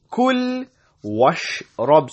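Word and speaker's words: كُلْ وَاشْرَبْ: كُل washrab, kul washrab. So we join the waw with the sheen.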